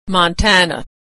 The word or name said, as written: Montana